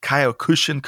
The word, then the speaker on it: Kyokushin: Besides being a second degree black belt in, I'm going to mispronounce this, Tapiwa, but Kyokushin Karate.